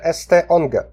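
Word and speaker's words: St. Onge: Peter ST Onge. Peter ST Ong, tak się wymawia.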